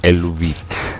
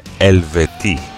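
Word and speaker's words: Eluveitie: Και πάμε σε μια μπάντα την οποία στην αρχή την πρόφερα σαν Ελουβίτ. Και όμως δεν λέγεται έτσι, προφέρεται Ελβετή.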